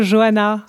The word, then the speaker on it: Johanna: C'est comme ça qu'on prononce votre nom oui, plutôt Johanna.